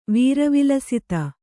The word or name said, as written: ವೀರವಿಲಸಿತ